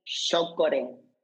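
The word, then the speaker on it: chókore: Se dice shokore, que es shiokore se escribe con C, shiokore, pero es, se pronuncia así como shokore.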